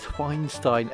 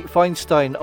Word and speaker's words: Feinstein: And hopefully that's the right way to say it. It's not Feinstein, but I think it's Feinstein. F-E-I-N-S-T-E-I-N.